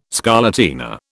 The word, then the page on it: scarlatina: British English